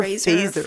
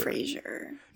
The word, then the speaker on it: Fraser: Is it Fraser or Phaser? – Fraser. – No, but there's an R after the F, I'm pretty sure. But then they were saying Phaser.